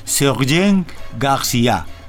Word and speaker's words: Sergent García: Originalmente se llama, usted me corregirá mi pronunciación en francés, pero se llama Sergent García. – Está usted muy bien, hermano.